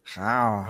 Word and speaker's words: rawr: Also R-A-W-R. – Das wird R ausgesprochen. Rav.